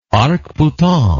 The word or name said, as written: arc-boutant